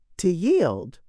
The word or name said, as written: to yield